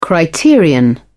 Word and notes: criterion: British English

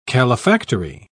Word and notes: calefactory: British English

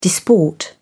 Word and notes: disport: British English

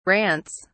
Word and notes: rants: British English

